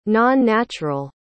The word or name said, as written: non-natural